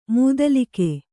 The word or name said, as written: ಮೂದಲಿಕೆ